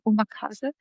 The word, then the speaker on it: Omakase: Was vielleicht viele schon gehört haben, ist Omakase, also europäisch ausgesprochen Omakase, also quasi Chef's Choice.